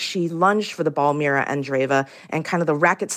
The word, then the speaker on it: Mira Andreeva: And what about, in the just minute we have left, this 16-year-old Russian, Mira Andreeva?